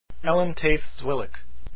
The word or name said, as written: Zwilich